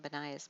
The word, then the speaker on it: Benaiah: His name is pronounced Benaiah, and it means built by Jehovah.